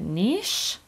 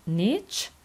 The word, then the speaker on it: niche: نیچ که تلفظ بریتیشش میشه نیش و امریکن میگن نیچ، به چه معنی هست؟